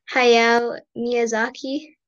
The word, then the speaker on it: Hayao Miyazaki: Uh, Walt Disney and I think I'm pretty sure it's pronounced Hayao Miyazaki.